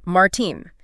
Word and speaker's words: Martijn: And it is spelled Martijn, pronounced Martine.